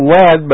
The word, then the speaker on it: Lead: You know, it's spelled lead, but it's called lead.